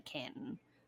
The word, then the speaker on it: Canton: And it's spelled Canton, but anyone from around here is going to call it Canton.